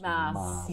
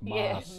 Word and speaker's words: Maas: Let's get on to the next company on the list, which is Maas Group, I never know how to say it properly. MGH, Maas. – Yeah, you would know that. – We Dutch, we know that. Maas. – Yeah, Maas. Okay, so, and it's a founder-led business.